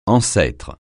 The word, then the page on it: ancêtre: francais